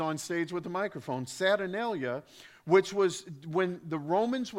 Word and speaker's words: Saturnalia: Along with that, there were Roman holidays known as the Saturnalia.